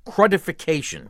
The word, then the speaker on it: crudification: The other possibility, and the more likely one, is that you have a severe case of fuel system crudification. – Witchification? – Crudification. – Could you spell that? – C-R-U-D. – Oh, crud. Crudification. C-R-U-D.